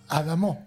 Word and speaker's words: Adamo: Qué buena pronunciación. Es la verdadera pronunciación en francés como lo hace don Juan Grunstro. – Te cuento, perdón. – No, sí. Donald Trump. – Adamo.